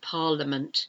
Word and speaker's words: parliament: But it's B-U-S-I-N-E-S-S. You don't pronounce that middle I, business. And parliament, parliament. It should be pronounced parliament, but it's not. It's pronounced parliament. It's a silent I, P-A-R-L-I-A-M-E-N-T.